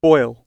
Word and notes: boil: British English

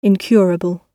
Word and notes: incurable: British English

